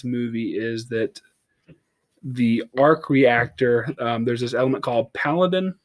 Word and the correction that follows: Palladium: that he has to use. Is it Paladin? Is that how you pronounce it? – Palladium? – Palladium. Thank you.